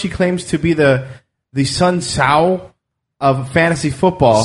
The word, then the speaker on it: Sun Tzu: It's Sun Tzu.